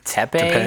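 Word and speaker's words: Teppei: I believe it's Tepe. – Tepe. – Tepe Taranishi. I looked up the pronunciation.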